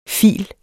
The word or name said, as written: file